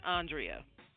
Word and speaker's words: Andrea: There's a thousand ways to pronounce it, but I pronounce it Andrea.